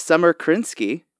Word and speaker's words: Krinsky: Today we have Summer Krinsky.